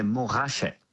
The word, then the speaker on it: Montrachet: Which actually in French, we don't pronounce the T, we say Montrachet.